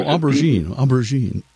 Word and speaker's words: aubergine: a color called Aubergine, if I'm pronouncing it right. And basically, it was purple. – Oh, aubergine. – Aubergine, yeah, see. I'm a Philistine there when it comes to that French pronunciation or whatever.